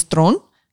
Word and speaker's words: Theron: Charlize Theron. That's how you say her last name, you guys. She's Afrikaans, yo.